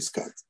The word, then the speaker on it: Scud: Los rusos, que son los, los ingenieros que fabricaron los scats Scud se escribe con U, pero se dice Scud.